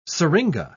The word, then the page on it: syringa: British English